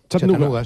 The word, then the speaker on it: Chattanooga: ¿Y se pronuncia así en Chattanooga? – Chattanooga, sí.